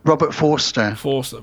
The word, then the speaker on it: Forster: Pronounced Robert Forrester. – Robert Forster.